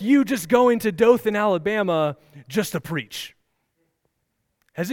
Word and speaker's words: Dothan: No, so Dothan, Alabama, am I saying that right? That reminds me of, what was the conch? What was that? You guys remember that? Right, the conch? See, I still can't say it right. So wild guess is Dothan. Yes, Dothan. Dothan is 100 miles that way.